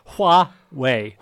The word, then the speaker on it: Huawei: Well, how am I doing on pronouncing Huawei? – Pretty good. Pretty good. Huawei.